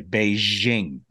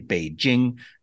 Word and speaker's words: Beijing: 99% say Beijing. They zhuzh it up, but it's actually Beijing. It's just straight J. There's no ZH sound, but everyone goes Beijing.